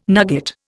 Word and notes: nugget: Indian

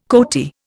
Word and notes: coati: Indian

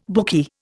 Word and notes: bookie: Indian

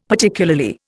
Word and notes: Particularly: Indian

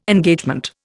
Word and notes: Engagement: Indian

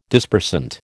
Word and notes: Dispersant: American English

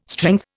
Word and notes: strength: Indian

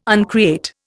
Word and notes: uncreate: Indian

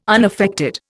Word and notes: unaffected: Indian

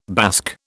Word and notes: Basque: British English

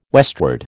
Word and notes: westward: American English

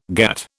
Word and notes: GATT: British English

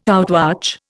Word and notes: outwatch: Indian

